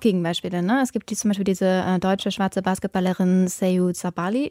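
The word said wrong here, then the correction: Satou Sabally: Ich glaube, ich habe es hoffentlich richtig ausgesprochen. – Satu Sabali, ja.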